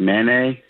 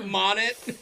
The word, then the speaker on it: Monet: That's how you pronounce it? I thought it was like Manet. – Monet.